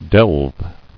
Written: Delving

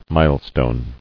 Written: Milestone

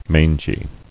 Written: Mangily

